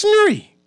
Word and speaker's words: Nuri: It's not hard to pronounce. It's not Chewy. It's not Luri. It's not Bowie. It's Nuri.